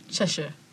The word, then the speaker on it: Cheshire: Is that how you pronounce Cheshire cat? – Cheshire.